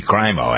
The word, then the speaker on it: Crimo: We pronounce it Cremo? Crimo? Cremo? It should be Crimo, actually.